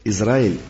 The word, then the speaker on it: Израиль: В слове «Израиль» Боря сделал ударение на последний слог.